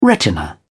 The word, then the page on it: retina: British English